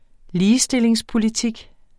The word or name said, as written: ligestillingspolitik